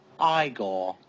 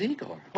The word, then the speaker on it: Igor: No, it's pronounced Igor. – But they told me it was Igor.